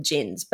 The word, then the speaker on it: djinn: It almost sounds racist when I call them djinns, but sorry. – Is that how you pronounce it? – The djinn. D is silent. – It's silent. D is silent, but it sounds ridiculous.